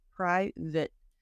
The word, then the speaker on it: private: Okay, so first pronunciation, private. So this one, if you look at just those four letters, it's tempting to say date, but it's actually but. So imagine like an I and no E.